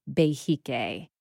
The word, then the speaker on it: Behike: You introduced me to this beautiful, new-to-me phrase, please correct my pronunciation, that you are a Behike? – Yes, Behike. Very good. – All right. – You got it.